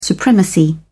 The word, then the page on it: supremacy: British English